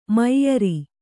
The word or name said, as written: ಮೈಯರಿ